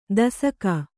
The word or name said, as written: ದೇಸಾಕೆ